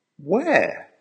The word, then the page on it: where?: American English